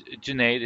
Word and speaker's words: Junaid: So I was like, yeah, my name is Junaid. It rhymes like lemonade.